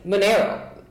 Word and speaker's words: Monero: We were talking the other day where I mined Monero, did I say it right? – Yeah, you're right. – Okay, I was like, I need to say it right.